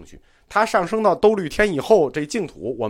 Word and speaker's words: 兜率天: 这个兜率天的读音也有同学给我纠正过。其实，嗯，就读兜率天吧，也应该就读兜率天。